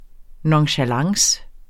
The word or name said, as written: nonchalance